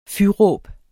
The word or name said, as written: fyråb